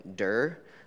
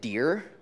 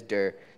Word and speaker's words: Derr: Last name, D-E-R-R. It sounds exactly the way it's spelled, Durr. But I can't tell you how many times people tried to be polite to me and pronounce it Deer, because they were like, ah, it couldn't possibly be Durr. Who would have such a weird last name? But it is Durr.